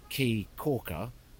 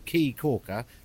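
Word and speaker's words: Caye Caulker: They here spell it C-A-Y-E. So where I went, the island is called Key Corker, but it's spelled C-A-Y-E, which surely spells K. I mean, for fuck's sake, can we not all get together and phonetically spell key in a way that we can understand? Anyway, if you get to Key Corker, C-A-Y-E, Corker, as in I put silicon around a bath, isn't that what corking is? C-A-U-L-K-E-R, I am a corker.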